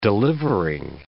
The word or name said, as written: delivering